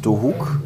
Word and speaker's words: Dohuk: Du bist ja selber mit neun Jahren aus Dohuk, mhm.